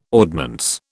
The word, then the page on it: ordnance: British English